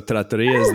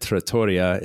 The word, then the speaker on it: trattoria: So trattoria also I always pronounce that in my head, trattoria, it's trattoria. Okay. Epic fail there.